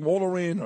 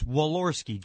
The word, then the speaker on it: Walorski: Hey, how do you say Jackie's last name? – It's Walorski. Jackie right. – Walorski. – Thank you.